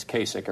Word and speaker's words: Kasich: By the way, it's Kasich. It rhymes with basic.